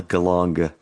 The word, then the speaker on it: Geelong: There's like kind of a Melbourne scene and kind of a Geelong scene. Hard G at the end.